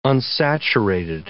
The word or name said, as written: unsaturated